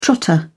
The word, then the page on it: trotter: British English